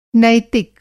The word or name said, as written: नैतिक